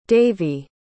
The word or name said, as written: davey